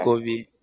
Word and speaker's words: Abokobi: It's Accra, Ghana, and I think it's Medina, Abokokobi, and then the number. – Abokobi. – Abokokobi. Abokokobi. Abokobi. Abokobi. Abokobi. Yes. – Yes, please. Abokobi.